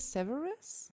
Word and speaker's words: Severus: Severus. – Sagt ihr Severus? – Ja. – Okay. – Was sagst du? – Ich bin mir nie sicher, wie es ausgesprochen wird.